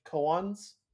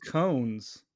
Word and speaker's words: koan: And then I can just, I did look it up and I think, yeah, Google says koan.